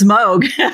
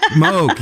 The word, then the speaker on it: Moog: Is it pronounced Moog? – I believe it's Moog. – Moog, yeah.